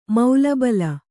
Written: ಮೌಲಬಲ

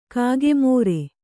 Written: ಕಾಗೆಮೋರೆ

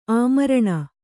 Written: ಆಮರಣ

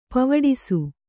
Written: ಪವಡಿಸು

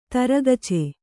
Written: ತರಗಚೆ

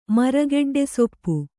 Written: ಮರಗೆಡ್ಡೆಸೊಪ್ಪು

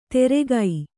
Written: ತೆರೆಗೈ

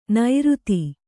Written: ನೈರುತಿ

